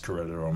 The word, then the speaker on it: Querétaro: Yeah, my daughter right now is serving a mission in Querétaro, Mexico. – That's cool, man. Which is pronounced Querétaro, Mexico.